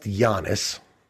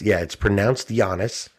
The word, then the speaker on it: Giannis: So his first name and it looks like Giannis, right? – Yes. – Right. – Yes. – So it's pronounced Giannis. – That's already confusing to me. – It's pronounced Giannis. I really genuinely am not even going to take a crack at his last name.